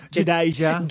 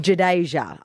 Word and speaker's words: Jadeja: Jadeja. – Jadeja.